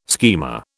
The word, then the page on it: schema: British English